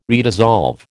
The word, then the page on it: redissolve: American English